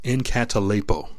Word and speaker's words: enkatalepo: And it's in the Greek, it's pronounced enkatalepo. Enkatalepo is the way it's pronounced, and it literally means to leave behind.